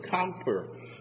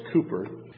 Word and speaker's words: Cowper: Cowper is how it looks in spelling, but it's pronounced Cooper.